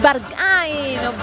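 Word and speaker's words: Berghain: La milésima intacha es la de Rosalía, Bargay. – Es Bargay, no como cada uno lo quiera pronunciar, pero efectivamente es esta canción súper conocida, lo decíamos, actual y que suena mucho.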